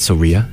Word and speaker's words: Soraia: Soria? I think that's how you pronounce it. Soria. S-O-R-A-I-A.